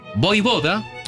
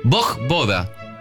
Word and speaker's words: Vojvoda: Se puede pronunciar como Vojvoda, claro. – en su forma original eslava, o como Bojboda, adaptado al castellano.